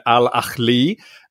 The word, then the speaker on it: Al Ahly: It's a club you guys haven't heard of, but it's Al Ahly.